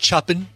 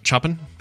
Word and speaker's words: Chopin: Customs officers at Frederick Chopin Airport... after the musician man guy. – I believe it's pronounced Chopin. – Chopin. – Chopin.